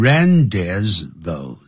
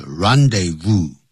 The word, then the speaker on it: rendezvous: 啊这个时候不能念成 rendezvous。那不是因为不是英文是 rendezvous, 哎就是一个场地 the place, 那就聚会的地方啊就是聚会的地方什么地方呢 du, 这是法文也就等于中文的英文的 of high life, 就是高级生活圈的聚会的地方。